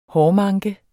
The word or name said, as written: hårmanke